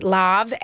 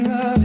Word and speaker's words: Lauv: We think we pronounce it Lav, L-A-U-V. – Love.